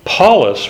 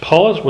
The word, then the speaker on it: Pallas: Paulus was the court favorite, and that's spelled P-A-L-L-A-S. So it's not P-A-U-L, it's P-A-L-L-A-S. Paulus was a court favorite.